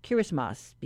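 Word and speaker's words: Kiritimati: Today it's known as Kirismas, because in the language of the Republic of Kiribati, the letters T and I are pronounced with an S sound.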